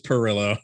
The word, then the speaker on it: Perillo: I always want to say it like, I always want to make it like fancy, like Perillo or something like that. – Yeah, no, yeah, it's just Perillo.